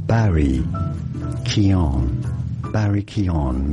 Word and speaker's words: Keoghan: Many say Keoghan. That's not correct at all. Barry Keoghan. Barry Keoghan. And now you know more. – Barry Keoghan is how you pronounce it. It's like the G is silent.